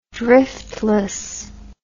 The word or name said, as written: driftless